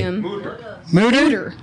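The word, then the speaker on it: Mütter: No, we went to the Mortar Museum. Is that how you say it? – Mortar. – Just say it real quickly. – Mooter. – It's got the umlaut.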